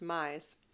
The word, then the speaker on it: Myss: Sorry, I believe her last name is pronounced Mize.